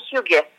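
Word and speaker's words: hygge: се с концепцията, по която, с която ти работиш, Хюга, Хю, Хюга, как се казва всъщност? – на българския хюга, а оригиналното произношение със сред да не го направя съвсем оригинално е хюге. Хюге.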